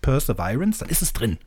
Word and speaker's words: Perseverance: Ich sage euch, wie es richtig ausgesprochen wird. Es heißt nämlich Perseverance. Ne? Ich mache es jetzt nochmal ohne das laute P-Laut. Perseverance.